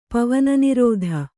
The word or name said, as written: ಪವನನಿರೋಧ